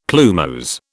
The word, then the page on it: plumose: British English